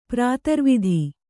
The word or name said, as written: ಪ್ರಾತರ್ವಿಧಿ